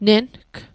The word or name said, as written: neng